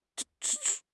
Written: 寻觅

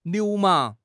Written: 流氓

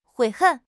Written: 悔恨

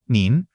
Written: nin2

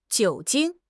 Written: 酒精

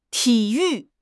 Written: ti3 yu4

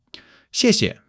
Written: 谢谢

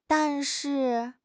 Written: dan4 shi4

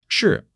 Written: shi4

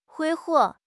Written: 挥霍

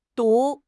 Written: du2

